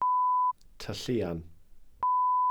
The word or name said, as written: tylluan